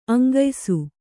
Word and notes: ಅಂಗಯಿಸು: aŋgayisu

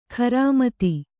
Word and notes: ಖರಾಮತಿ: kharāmati

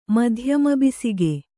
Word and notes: ಮಧ್ಯಮಬಿಸಿಗೆ: madhyama bisige

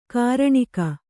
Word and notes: ಕರಣಿಗ: karaṇiga

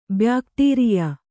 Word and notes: ಬ್ಯಾಕ್ಟೀರಿಯಾ: kannada; byākṭīriyā

